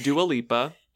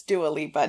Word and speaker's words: Dua Lipa: But as you said her name incorrectly twice, Lindsay was like, nope. It's Dua Lipa. – It's Dua Lipa.